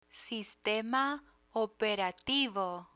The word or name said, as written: sistema operativo